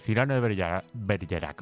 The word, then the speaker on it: Cyrano de Bergerac: Perdónenme, los que hablan francés, mi pronunciación. – Pero aquí en español lo decimos así, Cyrano de Bergerac.